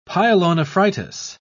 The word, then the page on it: pyelonephritis: British English